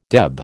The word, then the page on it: deb: American English